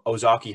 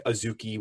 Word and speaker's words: Ozaukee: Noah Miller went 36 overall to the Twins, actually from Ozaukee High School. Uh, Manfred messed up the pronunciation, said, I think Azuki, Wisconsin, or Azuki High School.